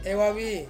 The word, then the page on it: wavi: Ansus